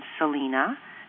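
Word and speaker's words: Selina: My first name, Selena, is spelt with an I, S-E-L-I-N-A, not to be confused with the Mexican singer, Selena, spelt with an E, S-E-L-E-N-A.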